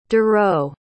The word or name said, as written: dureau